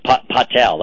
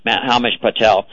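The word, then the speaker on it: Himesh Patel: When failing musician Jack Malik, played by Hamish Palek, I think that's how he pronounces his name, Patel, that's how it is, Hamish Patel,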